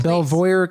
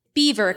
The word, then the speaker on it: Belvoir: That is incorrect. The correct pronunciation of the words you see in front of you. Beaver Castle.